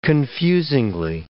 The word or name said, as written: confusingly